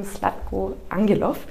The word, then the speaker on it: Zlatko Angelov: Ähm, diesmal mit einem Special Guest, nämlich dem lieben Slatko Angelov. Habe ich richtig ausgesprochen? Ja.